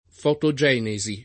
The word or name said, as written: fotogenesi